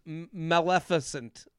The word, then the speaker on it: Maleficent: She looks, I'll describe her look as maleficent. What are the, how do you say that? – Oh yeah, Maleficent. Yeah, it's giving Maleficent.